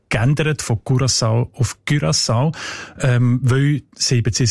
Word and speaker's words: Curaçao: Was sagt denn die Datenbank zu den Menschen von Curaçao? – Curaçao heisst die Insel, habe ich gelernt.